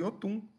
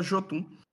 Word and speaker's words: Jotun: A pronúncia certa é Yotun. Yotun raivoso, mas todo mundo fala Jotun.